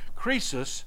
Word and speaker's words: Croesus: The ruler of Croesus, I always do his name wrong. It's got an O in there, it shouldn't be. Croesus, and then a philosopher, Thales.